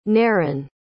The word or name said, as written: Neron